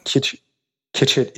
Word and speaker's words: Kitchit: Kitchit, it's K-I-T like Tom. – Yeah. – C like cat, H-I-T like Tom.